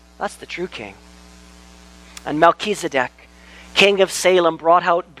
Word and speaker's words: Melchizedek: You want to pronounce rightly this one, though, because he's the only king that matters. Melchizedek.